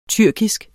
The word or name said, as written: tyrkisk